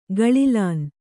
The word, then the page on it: ಗಳಿಲನೆ: gaḷilane